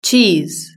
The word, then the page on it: cheese: British English